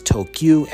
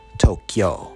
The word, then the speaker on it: Tokyu: It's Tokyu and not Tokyo.